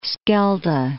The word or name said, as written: Schelde